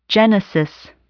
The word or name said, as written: genesis